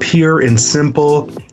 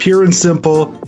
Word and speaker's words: Pure and Simple: It's called Pure and Simple, even though it looks like it's spelled purr, but Pure and Simple.